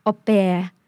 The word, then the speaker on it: au pair: Have you ever heard about au pair program? And what is an au pair? คำว่า au pair นะคะเป็นคำที่ยืมมาจากภาษาฝรั่งเศสนะคะออกเสียงว่า au pair au pair นะคะ au